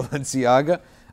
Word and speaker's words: Balenciaga: How do you say it? – Balenciaga.